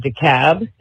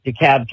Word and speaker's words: DeKalb: But I was calling to tell you that so the L in DeKalb is silent, just because I'm from Atlanta, and it can be very confusing. And I'm not getting on to you because a lot of people make that mistake. But if you ever have another DeKalb County situation, you can not have to try so hard to pronounce that L because it's a silent L.